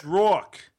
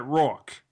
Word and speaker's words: Roark: Yes, it's like roar like a lion, and then the end of New York. Combine them, you got Roark. – Tanner Roark, like right. Rhymes with cork.